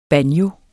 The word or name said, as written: banjo